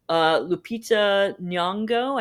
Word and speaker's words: Lupita Nyong'o: Lupita Nyong'o, I don't know if I'm pronouncing her name correctly.